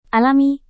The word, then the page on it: alami: indonesian